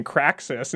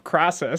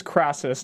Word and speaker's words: Crassus: And someone has been noticing that Spicy Mario's been saying Craxus instead of Crassus. – Oh, excuse moi. Okay. – So, Le Selva. – I've been YouTube. – Saying it wrong. What so, do I need to say? – Corrects you and says it's Marcus Crassus, not Craxus.